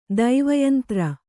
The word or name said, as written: ದೈವಯಂತ್ರ